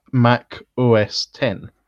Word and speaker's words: Mac OS X: Mac OS X is actually supposed to be pronounced Mac OS 10.